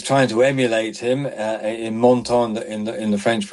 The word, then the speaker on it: Menton: I'm sorry if I've pronounced the French town of Menton incorrectly. – Menton. Yeah, Menton.